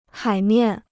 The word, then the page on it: 海面: hǎi miàn